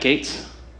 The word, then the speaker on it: Gaetz: Matt Gaetz. – Okay. So this fellow Matt Gaetz, who is a representative in Florida. – Can I set the scene about Matt Gaetz? – Yeah.